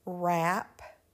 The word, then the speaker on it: wrap: However, the W is silent and we pronounce the R. Here are some examples. Wrap, wrap, wrap.